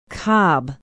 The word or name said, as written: cobb